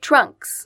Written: trunks

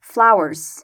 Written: flowers

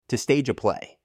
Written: to stage a play

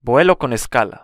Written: vuelo con escala

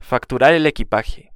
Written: facturar el equipaje

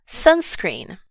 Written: sunscreen

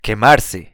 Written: quemarse

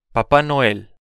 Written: papá noel

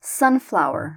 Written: sunflower

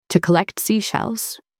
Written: to collect seashells

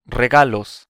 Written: regalos

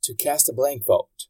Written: to cast a blank vote

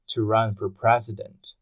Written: to run for president

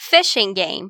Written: Fishing game

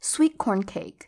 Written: sweet corn cake

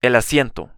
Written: el asiento

